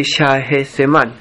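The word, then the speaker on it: Shahzaman: Aquí las H se pronuncia como una J, Shahariar y Shaezeman.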